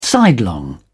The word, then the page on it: sidelong: British English